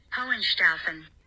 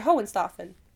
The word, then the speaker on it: Hohenstaufen: Okay, Matt, this is how you say it. – Hohenstaufen. – Yeah, I said it right. Hohenstaufen.